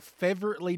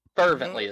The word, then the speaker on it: fervently: Fervently is the word you're looking for. – Fervent? I've never heard it pronounced. – Fervent. – I've only read it. I didn't have friends growing up. – You're pronouncing it like it's Brett Favre. – Fervently. Fervently.